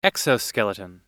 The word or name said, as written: exoskeleton